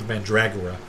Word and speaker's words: Mandragora: How's it pronounced? – Mandra- Mask of Mandragon. – Mandraga? Because it's spelled Dragora. – I think it's Mask of Mandragora, is how I've heard it.